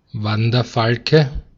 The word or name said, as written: Wanderfalke